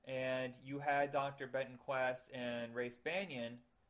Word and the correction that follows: Bannon: Is it Bannon or Banyan? I think I've been saying it wrong this whole time. I think it's Bannon. – It's Bannon.